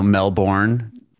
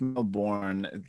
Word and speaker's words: Melbourne: I would accept Melbourne. I think it's just the quickness of the second syllable. It's just Melbourne.